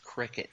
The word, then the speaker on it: Cricut: Cricut. It's pronounced cricket. – Oh, it's not cry cut? – No. I called it cry cut for the longest time. And people had to correct me because it's cricket.